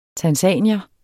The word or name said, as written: tanzanier